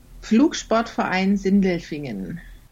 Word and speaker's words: Flugsportverein Sindelfingen: This month on Gliding Club Confidential, we go to the Black Forest region of Germany and the Flugsportverein Sindelfingen. – That's competition pilot pronouncing the name of her club in German.